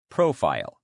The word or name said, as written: profile